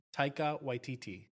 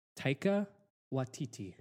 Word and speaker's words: Taika Waititi: How do you pronounce the director's name? – Taika Waititi. – Okay, cool. – Yeah. – All right. – I knew that she would be able to pronounce it, but it's great that you can too. – Yeah, yeah. He's a very large crush of mine. – Taika Waititi. – Waititi. – Waititi. – Mm-hmm.